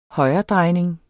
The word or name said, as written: højredrejning